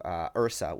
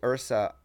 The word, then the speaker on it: IHRSA: around January, I told Monica that we needed to go to IHRSA, which IHRSA, I-H-R-S-A, it's spelled a little funny, is the most prominent sort of trade show in the fitness space.